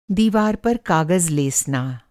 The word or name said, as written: लेसना